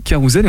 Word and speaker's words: Carousel: Voilà, la prononciation... voilà, c'est le, créateur lui-même qui m'a suggéré de, de prononcer ainsi Carousel et on va l'entendre tout de suite, c'est Vincent Thomasseux.